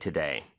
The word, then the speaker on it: today: 마지막 D 발음은 정말 약하게 해주시면서 today를 발음하는데 today도 이음절의 강세가 있는데 이 D 발음이 모음 소리 사이에 껴서 리을처럼 발음이 돼요.